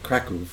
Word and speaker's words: Kraków: There's a woman that we met as we were the day before we left, and we met her in Krakow, the Poles pronounce that Kraków.